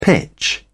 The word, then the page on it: pitch: British English